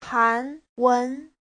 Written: han2wen2

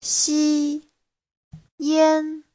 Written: xi1yan1

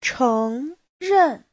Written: cheng2ren4

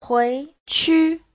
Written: hui2qu5

